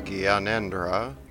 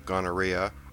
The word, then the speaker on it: Gyanendra: Nepal's King Gyanendra, not pronounced gonorrhea, was shocked to hear from his royal alchemist who'd watched CNN that morning that Iran wanted to invade.